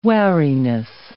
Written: wariness

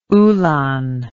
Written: ulan

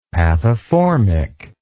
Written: pathoformic

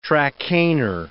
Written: Trakehner

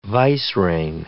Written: vicereine

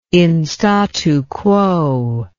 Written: in statu quo

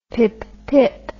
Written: pippip

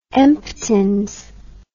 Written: emptins

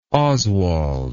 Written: oswald

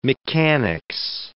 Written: mechanics

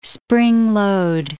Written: spring-load

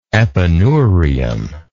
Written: epineurial

